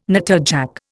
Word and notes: natterjack: Indian

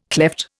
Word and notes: klepht: Indian

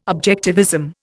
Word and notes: objectivism: Indian

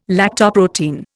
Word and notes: lactoprotein: Indian

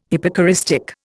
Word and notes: hypocoristic: Indian

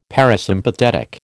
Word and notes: parasympathetic: American English

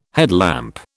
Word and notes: headlamp: British English

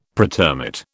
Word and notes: pretermit: British English

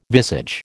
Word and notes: visage: American English

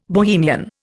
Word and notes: Bohemian: Indian

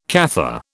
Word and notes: Cathar: British English

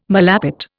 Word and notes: malapert: Indian

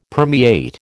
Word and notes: permeate: American English

